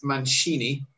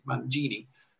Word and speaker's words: Mangini: My family pronounces Mangini as Mancini. Everybody else just reads it in Spanish as Mancini, and Italians pronounce it more like in gin, Mangini.